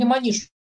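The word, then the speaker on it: Moniz: Нейрохирург Антонио Эгош Мониш, открывший, или Маниш, все-таки правильное ударение, он португалец, открывший терапевтическое воздействие лоботомии при некоторых психических заболеваниях, даже удостоенный за это Нобелевской премии, родился 150 лет назад.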